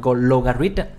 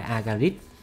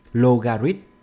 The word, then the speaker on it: logarithm: Không biết mình đọc đúng không, logarithm, logarithm nha, chứ không phải agarithm, logarithm.